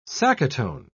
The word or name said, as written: sacaton